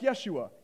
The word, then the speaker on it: Yeshua: In Aramaic, it's pronounced Yeshua.